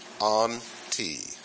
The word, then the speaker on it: anti: The first preposition is anti, A-N-T-I, anti. Stress on the second syllable, anti.